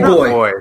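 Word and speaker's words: Boi: Is it Bua? – I think it's Boy. – I think it's big boy. – Boy. – Yes, it is.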